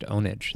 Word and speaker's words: Pwnage: So it's Pure Pwnage, by the way. – Showcase Pure Ownage. – TV show. No, it's with a P. – Yeah, but it's still pronounced Ownage, though.